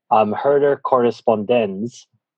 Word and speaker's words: Herder Correspondenz: And there's new text from the last episode that we did, and this was published in, and now I'm self-conscious because of my bad German pronunciation of the last episode, so I checked it ahead of time. Herder Correspondenz, the December 2018 edition, had this from Benedict XVI.